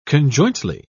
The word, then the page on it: conjointly: British English